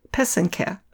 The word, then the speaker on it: pysanky: So it's spelled P-Y-S-A-N-K-Y. The accent is on the second syllable. An easy way to remember how to say it is that the first and third syllable rhyme. So that's the pe and the ke. Put it all together and you get pesenke.